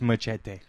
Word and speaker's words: Muschietti: Co-writer Gary Doberman and Muschietti, who did Mama. – It's pronounced Machete.